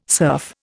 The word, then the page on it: serf: Indian